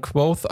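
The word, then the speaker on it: Kvothe: Und dann sagt er, das spricht man Quoth aus, das E ist stumm.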